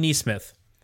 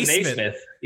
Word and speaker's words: Nesmith: Next guy I want to hit is Aaron Nesmith. So did I say his name right? We kind of went over that pre-show. I was scared of that name. Woof. – He kept calling him Naismith.